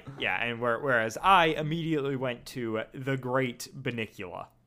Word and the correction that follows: Bunnicula: For the listeners, it's spelled Bunnicula.